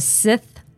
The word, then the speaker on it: Sith: kind of like the Sith Lord in Star Wars.